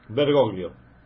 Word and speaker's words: Bergoglio: ¿Bergoglio? – Bergoglio.